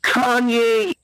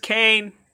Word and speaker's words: Kanye: Is there a Kanye East here? I'm looking for a Kanye East. – It's pronounced Kane. – What? – It's Kane. – What? – Yeah, like Dwayne, but like with a K.